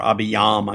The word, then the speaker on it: Abijam: I hesitated on that because you know in the First Kings passage, he's called Abijam or Abiyam. I guess you could pronounce the J with a Y sound.